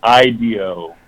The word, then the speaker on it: IDEO: Yeah, it's almost like idea, but you pronounce it IDEO. And it's spelled almost the same as IDEO, but there's an O on the end.